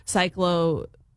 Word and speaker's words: cyclospora: We want to talk about this illness that is going around, this outbreak that the CDC is talking about, cyclospora. Am I saying it right? I hope I'm saying it right. Let me ask Dr. Peter Ward with the West Virginia School of Osteopathic Medicine. Did I say it right? – You got it right.